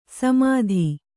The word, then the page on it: ಸಮೆತ: kannada